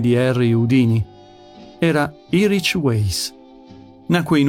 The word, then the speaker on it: Houdini: Uno di questi uomini è stato Harry Houdini, e non con l'accento sulla I finale, come erroneamente si pronuncia.